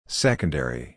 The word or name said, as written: secondary